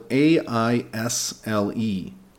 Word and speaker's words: aisle: And be careful with aisle, it's spelled A I S L E. But we say aisle.